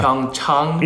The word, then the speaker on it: Pyeongchang: The correct pronunciation is Pyeongchang. – La prononciation correcte est Pyeongchang. – Like when you go see your doctor. – Comme quand on va voir le docteur. – Pyeongchang.